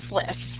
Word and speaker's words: SLIS: How do you pronounce that? – We pronounce it as SLIS.